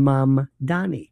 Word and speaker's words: Mamdani: Here in New York, people love to talk about the mayor, Zoran Mamdani. And they can't pronounce his name. Think mom and dad, Donnie Osmond, Mamdani.